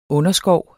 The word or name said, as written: underskov